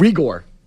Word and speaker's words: Reagor: Jalen Rigor. Rigor. – How do you spell it? – R-E-A-G-O-R.